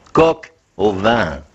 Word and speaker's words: Coq au Vin: Mais mon Dieu, il faut prononcer Coq au Vin.